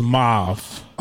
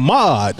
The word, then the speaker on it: mauve: It was mauve. – Okay. – Mauve. MAUVE. Mauve. – Oh, I've been saying it wrong. – Yeah. – I've been saying mod. – Nah, see? Mauve. See? It's mauve.